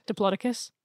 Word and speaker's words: Diplodocus: Pronounced Diplodocus, correct? – Diplodocus. – Why? You did not learn it like that.